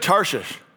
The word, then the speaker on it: Tarshish: Very good, Tarshish.